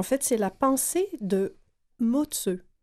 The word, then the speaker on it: Mozi: Et ça s'écrit M-O-Z-I, mais le Z-I se prononce « ce ». – Ah, on apprend quelque chose. – Oui. Moi, je pensais que c'était maudit. – Ah non, c'est Motzeu.